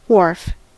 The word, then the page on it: wharf: [wɑːɹf]; /wɔː(ɹ)f/